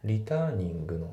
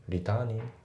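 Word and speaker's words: returning: で、発音なんだけども、リターニングの G のところが、まあ、抜けてますね。リターニング Your call、ね、リターニングって言ってないですね。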